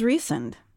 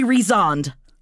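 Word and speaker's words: Rhysand: His name is Recent. And we have to stop here. This is how it is pronounced in the audible book. I know a lot of people... – In my brain, I say Rezond. – I also say Rezond. I don't think that that's the correct pronunciation of it. – Because the audiobook says Recent.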